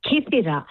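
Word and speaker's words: Kythira: How do you say the island again? – You said it right. Kithira.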